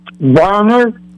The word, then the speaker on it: Varner: Varner. – Spell it. – V is in Victor. – Yep. – A-R-N-E-R. – N-E-R.